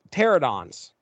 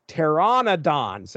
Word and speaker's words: pteranodons: Well, for the longest time, I'm reading this book and I'm saying pterodons, pterodons, pterodons, yeah. pterodons. That is not correct. It is pteranodons. There's an N, there's an NO in there I was completely missing.